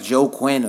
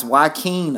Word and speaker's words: Joaquin: Because it looks like Joe Quinn or some shit like that. Look this nigga up. Look his name up. – Joaquin, he white? – It's Joaquin, I think. That's how you say it.